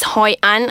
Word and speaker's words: Hoi An: Hoi An. I don't know if I'm pronouncing that right. – Yeah, you're right.